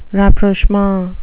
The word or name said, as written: rapprochement